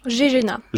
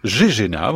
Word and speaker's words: GEGENA: Ardennes, dans un laboratoire, on prononce comment le nom de votre labo? – GEGENA. – GEGENA, voilà, ouais.